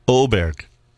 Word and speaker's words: Åberg: Åberg. I'm sorry, I'm pronouncing the umlaut and all the little things.